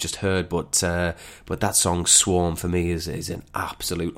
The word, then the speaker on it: SWVRM: Dead Poets Society, we are DPS on Twitter, and the song is Swarm, but it's spelled SWVRM.